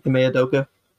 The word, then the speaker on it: Ime Udoka: To a team, and this is absent the Ime Udoka, Ime Udoka. Is that how you say his name? Ime Udoka? – Yeah.